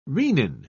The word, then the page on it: renin: British English